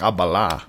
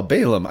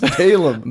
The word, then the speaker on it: Balaam: Well, you pronounce it like Abala. – Balaam. – No, Balaam. I know who you're talking about. – I don't think you do. – I was like, who's Ali Bam? – No, Balaam. Balaam. B-L-A-A-B-A-L-A-A-M. Oh, my gosh. – It's pronounced Balaam.